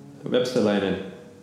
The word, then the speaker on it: Vepsäläinen: Vepsäläinen.